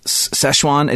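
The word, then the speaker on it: Sichuan: I believe it's pronounced Szechuan. It's spelled differently than Szechuan chicken.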